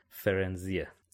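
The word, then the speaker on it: Firenze: آدم احساس میکنه باید فایر بخونه ولی در اصل اسمش فرنزیه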